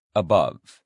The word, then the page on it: above: male